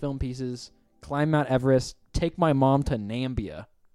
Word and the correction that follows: Namibia: Namibia? – Oh, yeah. Not Nambia. Whoops. No, yeah, I pronounced that wrong.